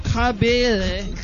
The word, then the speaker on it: Kabele: So, Pastor Frank Kabele, and that's exactly how it's pronounced because this is America, and that's how it's spelled.